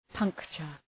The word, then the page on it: puncture: ‘pʌŋktʃər